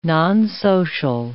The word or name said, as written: nonsocial